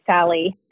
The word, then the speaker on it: SALI: Standards Advancement for the Legal Industry, SALI is how you pronounce it, Alliance.